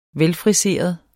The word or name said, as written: velfriseret